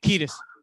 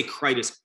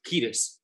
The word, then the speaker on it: Kiedis: Kiedis. – I always say Kritis. Kiedis. The first like minute or two,